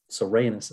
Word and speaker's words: Rhaenys: So Rhaenys is how I say that name.